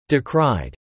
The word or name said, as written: decried